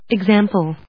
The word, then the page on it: example: ex・am・ple; ɪgzˈæmpl, eg‐ ｜ ‐zάːm‐